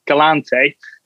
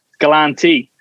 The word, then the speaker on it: Galante: And hearing many of his stories, he actually told us, you know, how you actually say his name properly, and it's not Galante, Galante.